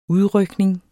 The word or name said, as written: udrykning